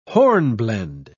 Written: hornblende

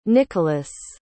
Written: Nicolas

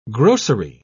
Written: grocery

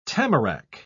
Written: tamarack

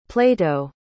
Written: plato

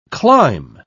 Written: clime

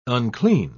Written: unclean

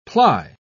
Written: ply

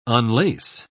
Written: unlace

